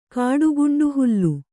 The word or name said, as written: ಕಾಡುಗುಂಡುಹುಲ್ಲು